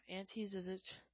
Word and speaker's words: Ante Zizic: Ante Zizic. That's how you say it.